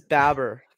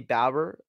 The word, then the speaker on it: Baber: I believe it's Baber. I think he said in his interview with me, Baber.